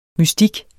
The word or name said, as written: mystik